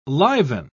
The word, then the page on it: liven: British English